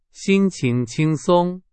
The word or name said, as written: 轻松